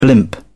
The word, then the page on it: blimp: British English